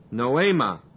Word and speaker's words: noema: So your minds, and the word minds is again noema, N-O long E-M-A, noema, which is used of the result of thinking,